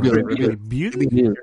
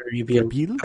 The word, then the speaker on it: Rebuild: De, este, no es parte 1, parte 2, no la tetralogía de Evangelion, el, el ¿cómo se pronuncia? – Rebuild. Rebuild.